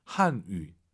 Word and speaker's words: 汉语: 한위가 아니라 와유 발음은 입술을 동그랗게 말아서 说汉语. 한위. 예, 위위 하시면 안 돼요.